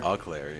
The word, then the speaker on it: Eau Claire: Eau Claire. I don't know how to pronounce it exactly. – Eau Claire, yes.